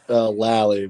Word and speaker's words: Lally: I'm saying that right. I'm making sure right. – Lally, but I mean, this is Lally, crazy.